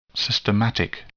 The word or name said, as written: systematic